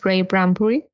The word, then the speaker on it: Ray Bradbury: Em, no sé si se diga, si se pronuncia así. El autor es Ray Bradbury. ¿Sí se dice así? – Ingeniero. Y, sí.